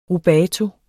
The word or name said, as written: rubato